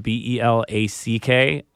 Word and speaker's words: Belack: But uh, yeah, but yeah, uh, my last name is spelled B E L A C K, um, despite everyone's poor pronunciation thereof.